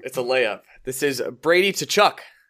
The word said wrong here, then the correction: Tkachuk: Nope. – Tkachuk. – Tkachuk. – Tkachuk. – The T almost doesn't exist.